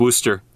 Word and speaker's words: Worcester: Worcestershire? – Yeah, and it's spelled Worcester. – Worcestershire? – It's pronounced Worcester.